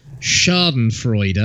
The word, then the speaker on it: Schadenfreude: No, I'm not a fan of Schadenfreude. I think I said that right. – Yeah.